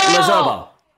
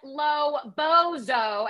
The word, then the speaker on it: Lozobo: I say it like Lozobo. – Oh, okay. I thought you said Lobozo, and I would go with that.